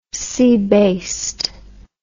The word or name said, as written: seabased